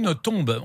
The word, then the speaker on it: Nothomb: Voilà, Amélie Nothomb, on a appris qu'il fallait prononcer le B.